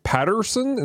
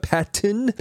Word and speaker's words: Pattinson: I kept calling him Robert Patterson instead of Patton, not Patterson.